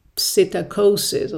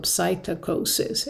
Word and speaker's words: psittacosis: And another chlamydia called psittaci can cause a disease called psittacosis or psittacosis. It's spelled P-S-I-T-T-A-C-O-S-I-S.